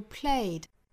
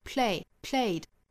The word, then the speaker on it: played: Howsoever you played, da da da da da da, 后面的 played, 那后面的这个 ed 呢是读浊音的 d 的发音 played, 一定要注意中间不要拖音，不是 play, 而是 played, 后面的 or by whom 是重点，前面两个词弱读。